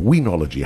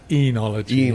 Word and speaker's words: oenology: Is it Oenology or weenology. How do you pronounce that word? – You spell it O-E, yeah, but you actually pronounce it enology.